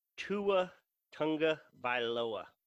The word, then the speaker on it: Tua Tagovailoa: I'm happy to eat the crow, is our new quarterback, Tua Tagovailoa. – Yeah, baby. – And I actually nailed it. – It was beautiful. You pronounced that amazingly.